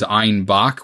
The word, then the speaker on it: Einbeck: If you're saying the beer from Einbeck, you're kind of pronouncing it as Einbach, which is German for a billy goat.